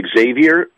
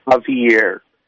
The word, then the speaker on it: Xavier: Mr. Becerra, you call him Xavier Becerra? His name is Javier. It's Javier, and that's how it's pronounced.